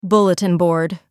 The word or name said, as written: bulletin board